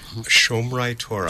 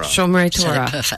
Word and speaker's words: Shomrei Torah: Am I saying it right? – Shomrei Torah. – Shomrei Torah. – Perfect.